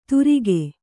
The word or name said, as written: ತುರಿಗೆ